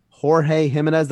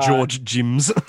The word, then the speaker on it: Jorge Jimenez: Jorge Jimenez, that's how it's pronounced. – Yes, not George Jims.